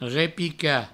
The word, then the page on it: repicar: repica; Provençal